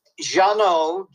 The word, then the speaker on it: Jeannot: Oh, it's Jeannot, J-E-A-N-N-O-T, Swark, S-Z-W-A-R-C.